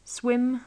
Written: swim

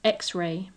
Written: x-ray

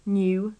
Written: knew

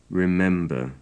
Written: remember

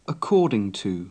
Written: according to

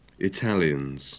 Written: Italians

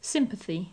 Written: sympathy

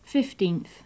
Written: fifteenth

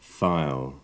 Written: file

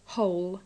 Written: whole

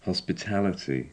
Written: hospitality

